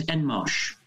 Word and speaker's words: Enmarch: The surname is Enmarch.